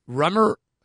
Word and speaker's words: Rumer: Excuse me, it's spelled R-U-M-E-R. That's why I spelled it. I knew it was Rumor. – Uh-huh. Yeah. – Didn't know that's how to pronounce it.